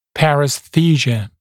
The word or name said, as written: paresthesia